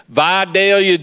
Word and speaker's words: Vidalia: Vidalia, Georgia. And Vidalia, Georgia is the sweet onion capital of the world. Vidalia onions. Y'all had Vidalia, you get Vidalia onions up here? I love grilling Vidalia onions.